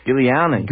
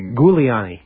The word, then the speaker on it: Giuliani: It's pronounced Gilly... – Giuliani. – Giuliani.